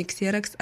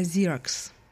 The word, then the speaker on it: Xerox: Американская компания, которая для нас звучит как Xerox, произносится совсем не Xerox, а Xerox.